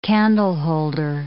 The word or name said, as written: candleholder